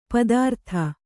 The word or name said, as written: ಪಥಾರ್ತ